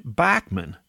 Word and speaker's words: Bachman: Interesting, he pronounces his name Bachman. There are videos of him pronouncing it that way, and I guess he should know.